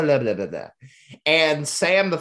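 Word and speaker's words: SA5M: And listeners, if you're curious why we keep referring to her as Sam, the Five is silent, it's because this woman's name is spelled S-A, the number five, M. – But the five is silent. – And it is pronounced Sam, the Five is silent.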